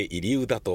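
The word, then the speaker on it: 入生田: 入うだ入るに生きるに田んぼの田と書いて入うだと読むそうです。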